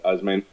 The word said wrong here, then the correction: Ozymandias: Ozymandias, right? Yes.